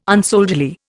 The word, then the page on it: unsoldierly: Indian